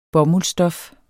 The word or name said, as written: bomuldsstof